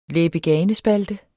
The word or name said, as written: læbe-gane-spalte